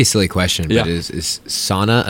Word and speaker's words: sauna: He has a sauna in his house, and he is Finnish, so we have to pronounce it sauna whenever we talk about it. It's not a sauna.